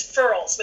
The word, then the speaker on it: furls: They get goosebumps or gooseys, or it's also known as furls, which rhymes with curls, F-U-R-L-S.